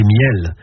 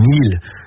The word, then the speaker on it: miels: Ce sont les miels, qu'on prononce aussi les mils, du côté de Barneville-Cartrais.